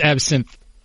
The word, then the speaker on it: absinthe: That's a different absence. Just saying. – That's absinthe. – Oh, okay. You know, it's all about pronunciation.